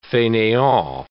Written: faineant

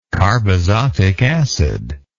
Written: carbazotic acid

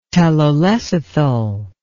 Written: telolecithal

